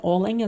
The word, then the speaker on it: Oling: And Oling is spelled O-L-I-N-G.